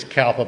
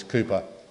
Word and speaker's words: Cowper: I know it's, you read it as Cowper, but he pronounced it as Cooper.